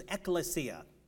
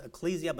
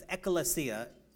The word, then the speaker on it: ekklesia: The word church is, the word that God chose to define the word church is ekklesia. It looks like it's pronounced ecclesia, but it's ekklesia in the Greek.